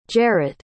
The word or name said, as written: jarett